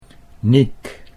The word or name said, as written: Niek